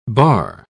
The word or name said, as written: barre